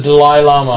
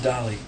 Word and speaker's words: Dalai Lama: The Dalai Lama. – I thought it was Dali. – No, you mispronounce it because you're stupid.